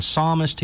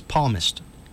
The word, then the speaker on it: psalmist: Quote, devout Catholic Joe Biden attempts to quote the Bible, but instead of saying psalmist, he says palmist. – No, he pronounces the P and doesn't pronounce the S.